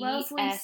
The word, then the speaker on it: Wellesley: Is that how you say it? Wellesley? – Wellesley? – Wellesley. – That's what it looks like, but I'm not sure. – W-E-L-L-E-S. – Wellesley sounds right. – L-E-Y.